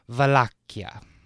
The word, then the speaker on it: Wallachia: Upon his trusted ally, Prince Mircea the Elder of Wallachia. – Wallachia. – I know Wallachia. – That sounds like a cool, that's a cool name. – There's different ways to pronounce it. That's the actual Romanian pronunciation.